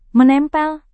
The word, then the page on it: menempel: Indonesian